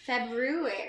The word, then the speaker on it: February: It really has an R. February.